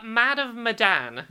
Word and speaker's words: Man of Medan: How do you pronounce that new game they're doing? – Man of Medan. – Man of Medan, right.